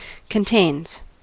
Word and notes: contains: American English

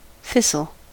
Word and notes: thistle: American English

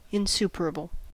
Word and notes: insuperable: American English

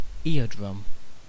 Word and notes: eardrum: British English

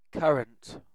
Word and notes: currant: British English

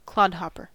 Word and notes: clodhopper: American English

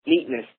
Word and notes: neatness: American English

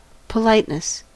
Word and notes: politeness: American English

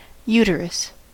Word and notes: uterus: American English

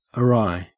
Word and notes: awry: Australian English

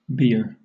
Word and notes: bier: British English

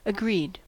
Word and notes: agreed: American English

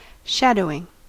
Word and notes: shadowing: American English

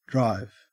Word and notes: drive: Australian English

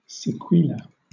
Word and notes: sequela: British English

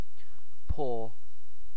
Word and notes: paw: British English

